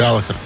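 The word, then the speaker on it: Галлахер: Галахер. – Галахер. Я постоянно путаю ударение. Галахер, а, правильно. Да.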